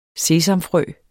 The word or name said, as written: sesamfrø